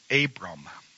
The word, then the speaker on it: Abram: Well, this week, we have another covenant with that God established, and this was with a man named Abram. And no, I'm not saying that wrong. I'm not messing it up.